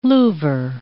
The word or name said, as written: louver